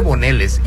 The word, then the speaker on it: boneless: Porque yo escucho a mucha gente que dice boneles y otros pues es que en español boneless. Es boneles, pero la pronunciación en inglés es boneless.